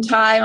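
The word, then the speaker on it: Tighe: So Corinne Tai, my last name is spelled T-I-G-H-E. I also have Instagram, R-I-N-T-I-G is my handle.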